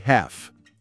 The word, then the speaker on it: half: E half, metade. – Ouçam com atenção. Child, pausa. Half. O L é mudo, né? Half.